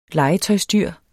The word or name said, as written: legetøjsdyr